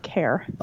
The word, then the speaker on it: Kerr: My husband is Scottish, and apparently in Britain you can say it one of two ways, and we say it care.